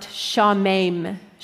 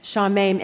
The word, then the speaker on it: shamem: The Hebrew verb here, or the Hebrew word, is a verb that is pronounced shamem, shamem, and it means to be appalled or dismayed.